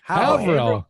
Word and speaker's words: Haverhill: I'm not gonna pronounce it. Is it Haverhill? – Haverhill. Oh, that's where we buy a lot of our multifamilies.